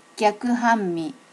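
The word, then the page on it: gyakuhanmi: native Japanese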